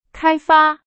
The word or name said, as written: 开发